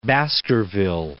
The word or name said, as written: Baskerville